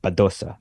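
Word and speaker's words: Badosa: Bedosa won, or as I should say, because I've started listening to the tennis podcast. And they have good pronunciation. And they go, Bedosa.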